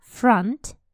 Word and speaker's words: front: Передний front, front, хотя пишется с буковкой O, но произносится А, front, и название улицы, где все происходит, Privet Drive.